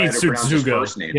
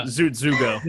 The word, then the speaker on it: Tsutsugo: Yeah, Yoshi Tsutsugo. – Yeah, Tsutsugo.